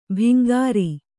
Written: ಭಿಂಗಾರಿ

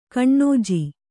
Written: ಕೊಞ್ಞೆ

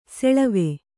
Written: ಸಳವ

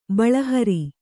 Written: ಬಳಹರಿ

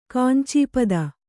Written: ಕಾಂಚೀಪದ

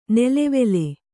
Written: ನೆಲೆವೆಲೆ

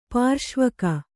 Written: ಪಾರ್ಶ್ವಕ

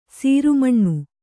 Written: ಸರಮೀನ

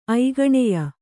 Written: ಐಗಣೆಯ

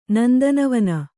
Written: ನಂದನವನ

